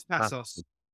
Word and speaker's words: Passos: It's Passos.